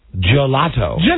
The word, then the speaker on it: gelato: Gelato. – It rhymes with one of Rob's favorite words. Have you heard Rob say that word before? Mulatto.